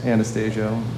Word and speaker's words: Anastasio: The oft mispronounced Anastasio.